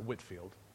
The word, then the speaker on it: Whitefield: Now, if you look at the word Whitfield, it's spelled Whitefield, but you pronounce it Whitfield.